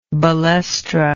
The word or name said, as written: balestra